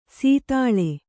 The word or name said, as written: ಸೀತಾಳೆ